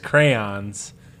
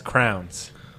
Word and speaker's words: crayons: But apparently, Caveman says crayons, pronounces it as crowns. – Why would you go so long to say crayons? That's way too long. When you say just grab your crowns. – Because that's how it's pronounced.